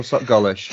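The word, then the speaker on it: SocGholish: Am I right in saying that? Sock Ghoulish or Sock Ghoulish? – Sock Ghoulish. I pronounce it Sock Ghoulish. It's kind of like Revel, Revel. There's different ways of pronouncing it. I just go by Sock Ghoulish.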